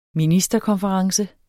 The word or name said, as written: ministerkonference